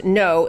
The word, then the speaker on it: Ngo: You know, this weekend's violence in Portland, I told you just one story involving this is a guy named, last name is NGO, pronounced No, Andy No.